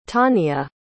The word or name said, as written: tawnia